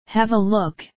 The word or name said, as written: have a look